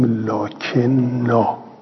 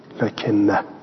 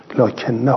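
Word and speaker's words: لٰكِنَّا: یه کلماتی توی قرآن کریم هست که رسم الخطش یه جوره و تلفظش یه چیز دیگه. این توی سوره کهف می نویسیم لکن نا، می نویسیم، ولی باید بخونیم چی؟ لکن نه، لکن نه هو الله ربی.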